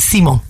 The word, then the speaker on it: Simon: His name's Gerald Simon, but in French, Gerald Simon. – Gerald Simon. – Simon. No, you don't pronounce the N. Simon.